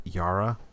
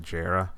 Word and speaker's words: Jara: I thought they were Italian my whole life, but their name is like you pronounce it Yara. I said it Jara for years, but yeah.